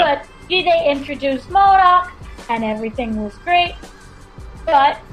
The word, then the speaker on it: MODOK: The characters of, what was it, Modok? I can't pronounce his name. Is it Modok? M-O-D-O-K? – I think is it Modok or... Modok. Gary probably knows, because I know he's been reading those comics.